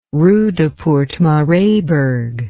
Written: Roodepoort-Maraisburg

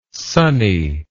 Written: sonny

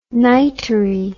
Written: nightery

